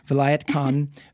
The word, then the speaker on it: Vilayat Khan: Vilayat Khan, who is a classical sitar player.